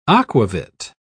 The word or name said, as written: aquavit